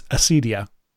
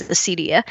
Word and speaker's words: acedia: And that is the correct pronunciation, doesn't it? It's acedia. – That's the way I've always said, and I've always heard it said, is acedia.